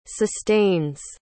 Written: sustains

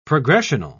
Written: progressional

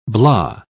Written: blah

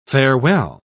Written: farewell

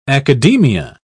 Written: academia

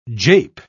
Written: jape

